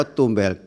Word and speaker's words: Tumbel: Tumbel nih, membacanya Manado? – Tumbel Yang Mulia. – Oh Tumbel, Chris Tumbel ya, selaku kuasa hukum pemohon.